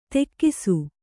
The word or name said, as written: ತೆಕ್ಕಿಸು